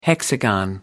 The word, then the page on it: hexagon: American English